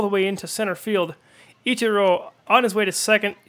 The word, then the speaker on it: Ichiro: It's funny because I believe the proper Japanese pronunciation is Ichiro, but yes. – Here over in the States, we change it to Ichiro. We put the accent on the first syllable.